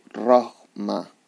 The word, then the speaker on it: Rohmah: Lo digo así porque es R-O-H-M-A-H. Roma. Me parece que se pronunciará.